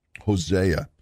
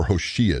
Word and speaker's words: Hosea: And then keep going to the right, and you're going to find Hosea, or Hoshea, depending on how you want to pronounce it.